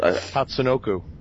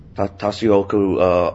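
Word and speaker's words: Tatsunoko: And so, yeah, so after that, we went to play the other one, which was Capcom versus Toshinyaku. How do you say it? – Tatsunoku. – Tatsunoku All-Stars.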